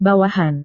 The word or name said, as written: bawahan